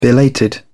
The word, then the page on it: belated: British English